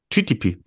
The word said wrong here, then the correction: GPT: GPT. – Das P kommt vom T. T, ja. – Gut. Also, ihr Lieben da draußen, ihr wisst, Chat GPT. So spricht man es aus.